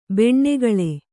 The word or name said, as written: ಬೆಣ್ಣೆಗಳೆ